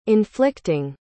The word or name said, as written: inflicting